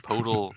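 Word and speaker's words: Poeltl: So, oh, Bob, which is weird because calling in, he's saying Podle rhymes with turtle.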